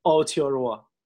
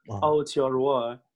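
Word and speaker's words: Aotearoa: اون اسمی هم که گفتین که تلفظ ماوریش هست آوتیاروا آوتیاروا که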